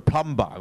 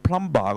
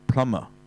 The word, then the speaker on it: plumber: 其實嗰陣時阿奧巴馬咧競選嗰陣時咧，就係遇到一個人咧，佢啲人都講嘅就係叫 Joe the plumber 咁啊嚇。咁但係咧後嚟我先知，咦唔係喎，佢哋又係從來唔敢發音。我睇嗰啲荷里活嘅電影先知，原來咧佢唔係讀 plumber 嘅喎，佢係讀 plumber。